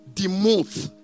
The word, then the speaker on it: demuth: And the Hebrew word for his likeness is demuth, but it is spelled D-E-M-U-T-H, demuth.